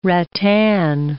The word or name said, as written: rattan